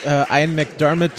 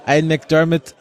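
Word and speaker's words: Ian McDiarmid: Und ich muss aber auch sagen, für das, was Ian McDermott, so spricht man ihn übrigens aus, die Frage kam auf, Ian McDermott gegeben wurde an, sagen wir mal, Dialog und Set-Design und so, hat er das wirklich exzellent gemacht.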